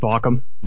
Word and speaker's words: Baucom: Yeah, is that how you pronounce your last name? We, we were arguing about that. – It's uh, pronounced Baucom.